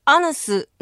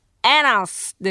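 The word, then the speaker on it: anus: 板橋区の三大都市の制覇さん、ウイカさん、エーノーの名詞形アヌスの正しい発音はエナスです。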